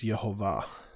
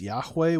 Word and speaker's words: Yehovah: And the way he says it should be pronounced based on that evidence is Yehovah. Okay, it's Yod-Heh-Vav-Heh. The traditional way that Christians often say is Yahweh, which is not necessarily incorrect, but it's just not with the full set of vowels.